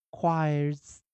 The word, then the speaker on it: choirs: 那 choir 如果加上复数形，记得 choirs。 choirs 要念 z 啊，choirs，choirs，choirs。